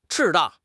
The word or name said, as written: 赤道